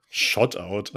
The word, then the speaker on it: Shoutout: Ich habe jetzt in diesem großen Raum, wo sozusagen vorher das Bett und ähm, der große Schrank stand, der Schrank, Shutouts. Oh, das war richtig cringe. Shoutout an Fendi. Ich habe Shoutout falsch gesagt. Shoutout.